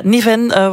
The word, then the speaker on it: Neven: Want vanochtend heeft de Waalse minister voor Klimaat, Cécile Neven, of Niven, hoe spreek je het uit, van MR gereageerd ook op die rampen? – Ja, Niven was te gast bij Matin Première, bij onze collega's van de RTBF.